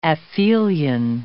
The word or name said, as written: aphelion